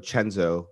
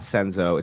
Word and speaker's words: Cenzo: Use code CHENZO, C-E-N-Z-O. – CHENZO. – It's not Senzo, it's Chenzo. – But Senzo will work. – If you spell it C-E-N-Z-O and you say Senzo as you're typing it in, it'll still work.